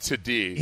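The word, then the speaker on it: Tuddy: Oh, by the way, it's Major Tutty. It's pronounced Tutty.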